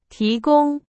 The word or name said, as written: 提供